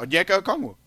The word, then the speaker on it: Onyeka Okongwu: Yeah, Onyeka Okongwu. – Really? – Yeah, Onyeka Okongwu.